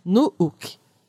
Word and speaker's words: Nuuk: E quando a gente tem vogais duplas, elas são, inclusive, pronunciadas separadamente. É, significa assim, não é que é para falar uma vogal estendida, uma vogal longa. Você tem que pronunciar as. Eles pronunciam duas vogais. Então, a capital da Groenlândia é Nuuk.